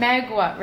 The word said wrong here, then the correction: Mauga: right, or well, how do you pronounce Mauga. – It? – Mauga.